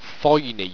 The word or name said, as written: thoiny